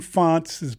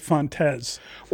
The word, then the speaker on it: Fontes: Wayne Font's brother was on his coaching staff. – Yes, I know. – And he pronounced it Fontes.